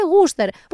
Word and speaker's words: Worcestershire: Είναι σαν τη Worcester Sauce που γράφεται Worcestershire, αλλά λέγεται Worcester, που κανείς δεν έχει καταλάβει γιατί βάζουν ενδιάμεσα 17 διαφορετικές συλλαβές και σύμφωνα για να μην τα προφέρουνε.